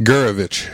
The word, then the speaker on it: Gurevich: Gurevich. – Gurevich. – It's, you know, two or three syllables depending on how Russian you want to sound.